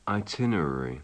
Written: itinerary

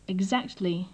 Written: exactly

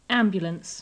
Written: ambulance

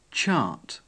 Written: chart

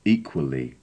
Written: equally

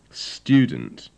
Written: student

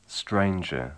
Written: stranger

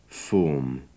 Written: form

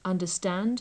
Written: understand

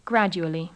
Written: gradully

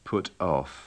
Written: put off

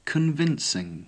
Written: convincing

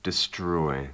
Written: destroy

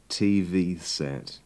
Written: tv set

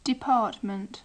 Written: department

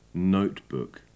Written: notebook

